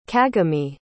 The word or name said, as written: kagami